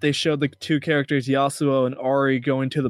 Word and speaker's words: Yasuo: Yes, Yasuo.